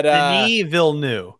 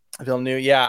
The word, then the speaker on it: Villeneuve: I don't know how to say his last name. But Denis Villeneuve. – Villeneuve, yeah.